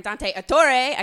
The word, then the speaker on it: Dante Ettore: and he's like my friend Dante Ettore.